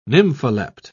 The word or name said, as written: nympholept